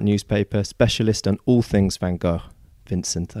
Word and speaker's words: Van Gogh: I'm afraid not, but it's very difficult to say correctly because the English find it almost impossible to pronounce the Dutch, which is something like Van Gogh, but even I can't do it properly. But it is complicated because in America he's called Van Gogh, and in France they all call him Van Gogh.